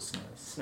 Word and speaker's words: SNES: Is going to go first on his SNES or SNES or how do you pronounce that? – I just call it SNES.